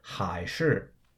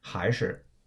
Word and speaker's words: 还是: Then I say the word for or, which is what you're going to hear a lot in Chinese, which is 还是, right? Also sometimes pronounced 还是, right? So that's H-A-I in the second tone, 还, and then S-H-I either in the fourth tone, 是, or S-H-I in the neutral tone, 是, right?